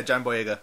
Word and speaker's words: Boyega: Or was it this week where, no, it was this week with John Boyega. Did I say his name correctly? – Yeah, John Boyega.